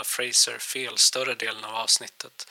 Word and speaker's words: Fraser: inte Frasier som jag av lite oklar anledning hade fått för mig.